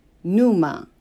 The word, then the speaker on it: pneuma: One, a demonic part, chous, a rational soul, psyche, psy, C-H-E, and a spiritual seed, pneuma, P is silent, P-N-E-U-M-A.